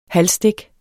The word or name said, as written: halvstik